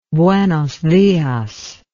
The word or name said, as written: buenos dias